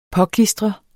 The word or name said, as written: påklistre